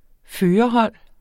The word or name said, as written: førerhold